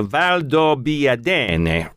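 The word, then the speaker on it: Valdobbiadene: Prosecco is a sparkling wine that comes from the, and I'll check me on my pronunciation here, the Val d'Obbiadene region in the Veneto. – Close enough. – That was pretty good.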